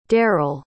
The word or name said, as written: derrill